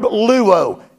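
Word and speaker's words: luo (λύω): It is the Greek verb luo. It is spelled L-U-O.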